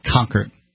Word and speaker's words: Concord: It's the Concord police chief, everything in New England, it seems, they put the accent on the first syllable, so it's a Concord.